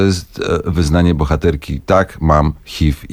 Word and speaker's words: HIV: Które w Polsce zdecydowanie wymawiamy jako HIV z takim polskim F, ale tak naprawdę to jest HIV z wyrazistym V.